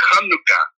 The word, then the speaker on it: Hanukkah: and that Hanukkah begins with a C-H, so that's the Hebrew sound, and the correct pronunciation is Hanukkah.